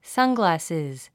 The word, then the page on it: sunglasses: sʌ’ng`læsiz; サングラッシズ